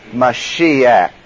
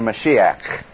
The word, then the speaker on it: Mashiach: Now, if you want to be a purist about the way you say it, actually it gets pronounced a little bit different in Hebrew, in that it sounds something like Mashiach. And that gets sort of a sharp expression there, Mashiach.